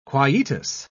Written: quietus

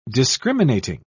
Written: discriminating

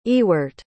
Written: eawart

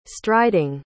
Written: striding

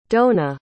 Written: doanna

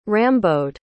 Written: Rambaud

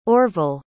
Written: orvelle

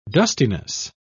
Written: dustiness

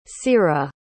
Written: ciera

